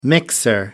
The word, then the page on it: mixer: American English